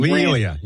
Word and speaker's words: Lee Elia: Hey, I was just, first thing that pops into my mind every time I hear about someone ranting is the Lee Elia. I think that's how you say his name. – Lee Elia, yep.